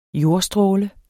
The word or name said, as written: jordstråle